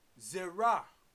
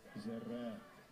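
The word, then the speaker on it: Zerah (זֶרַע): Now the word seed, Zerah, 2233. Let's hear the pronunciation. – Strong's H2233. Zerah. Zerah.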